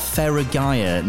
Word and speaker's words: Feragaia: 2024 is here, and I should say a very happy new year to Jamie Wild, who's the co-founder of Feragaia.